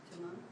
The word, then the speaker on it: Timon: Timon. – Okay, you. And you know why you guys pronounce it Timon? – Yes. Yeah. – Hakuna Matata, baby.